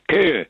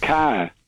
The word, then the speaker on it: Kerr: Josh Kerr. K-E-R-R. Sometimes it's pronounced Carr.